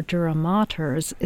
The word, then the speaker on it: dura mater: It's spelled D-U-R-A, dura, mater, M-A-T-E-R, so that's the play on words, mater, maters.